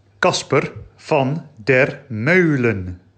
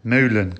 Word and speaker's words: Kasper van der Meulen: Wir reden heute über ein Buch eines holländischen Autors und ich, ich habe die ganze Zeit während der gesamten Folge diesen Namen komplett falsch, nämlich komplett deutsch ausgesprochen, weil ich es nicht besser wusste.